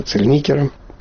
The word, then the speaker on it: Цельникер: как правильно ставить ударение в его фамилии, Цельникер или Цельникер. И вот так всю жизнь мы спорили в классе, как его правильно называть. Сам он называл себя Цельникером.